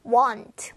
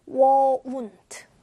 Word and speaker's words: won't: 인데, won't 들을 때도 주의 깊게 들었지만 발음할 때도 want 원하다가 아니라 won't 우 발음이 조금 더 들어가 있다는 걸 주의하고 발음해 주세요.